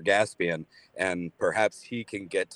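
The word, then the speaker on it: Gaspain: Gaspian. – Oh, Gaspian, like gasoline. – Correct.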